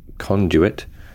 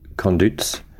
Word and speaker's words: conduit: Seeing as Andy was curious, I asked around the island and we officially pronounce it conduit, not conduits.